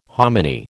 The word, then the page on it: hominy: American English